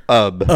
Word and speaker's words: Ub: UB Iwerks is spelled UBIWERKS. First name, UB, UB. Last name, Iwerks, Iwerks. UB's full name, UBBE. – Uber. – Also pronounced UB. – UB, but longer.